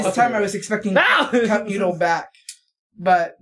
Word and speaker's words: Cup Noodle: So last time I came here, I brought you guys Cup of Noodle. – Cup Noodle. – Cup Noodle. – Say it right.